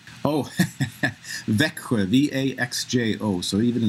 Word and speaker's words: Växjö: Växjö.